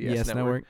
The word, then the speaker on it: YES Network: No, it might be like, it might stand for something, but they pronounce it the YES Network.